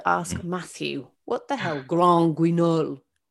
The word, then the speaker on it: Grand Guignol: Have I said that right? – I think it needs to be more French, sort of Grand Guignol. – Okay. Yeah, I think it's Grand. – There you go. It's Grand Guignol.